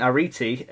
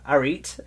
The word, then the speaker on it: Arete: it's spelled A-R-E-T-E. And when I was in school learning this, we all called it, because we're Yorkshire, Arete. – It probably is Arete, because Crete is spelled like that, but with a C. – No, it's Arete, I believe.